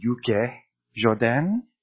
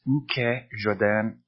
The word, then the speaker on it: ukejoden: So people will say ukejoden, which is incorrect. It is a hard U, so it is always ukejoden, like ukemi, the same idea.